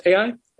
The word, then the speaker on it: Ai: So part 4 of Joshua, I believe it's pronounced AI. Ai and Achan.